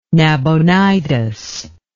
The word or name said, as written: Nabonidus